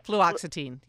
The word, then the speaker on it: Fluoxetine: I hope I'm pronouncing it correctly. Fluoxetine. – Fluoxetine, yep.